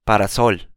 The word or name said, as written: parasol